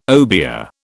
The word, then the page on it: obeah: British English